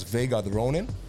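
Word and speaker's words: Vega7 The Ronin: En je schrijft het Vega 7, The Ronin. Maar volgens mij spreek je het gewoon uit als Vega The Ronin.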